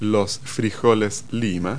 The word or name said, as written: lima bean